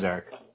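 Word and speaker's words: Erik: My name is Eric.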